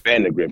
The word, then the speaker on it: Vandagriff: Vandagriff.